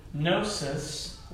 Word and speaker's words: gnosis: Gnosticism comes from the Greek word gnosis, that the G is silent there when they come together.